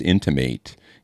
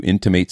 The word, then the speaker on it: intimate: See, now that there's an interesting word, is intimate. You intimate something or something is intimate, right? Mm. It's spelled the same, but they're different, right? – Right, right. – Yeah. One is a noun, one's a verb.